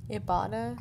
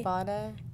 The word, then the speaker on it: Ibotta: Ibotta. – Ibotta.